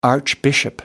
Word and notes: archbishop: American English

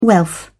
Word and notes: wealth: British English

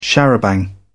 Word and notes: charabanc: British English

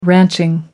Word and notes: ranching: American English

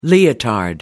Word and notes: leotard: American English

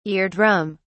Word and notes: ear drum: British English

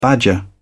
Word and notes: badger: British English

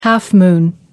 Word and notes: half-moon: American English

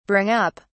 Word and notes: bring up: British English

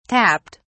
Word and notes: tapped: British English

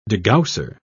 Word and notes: degausser: British English

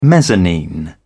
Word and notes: mezzanine: British English

